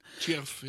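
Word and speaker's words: Tschierv: Juni in Chierf, habe ich das jetzt richtig ausgesprochen? – Chierf, ja.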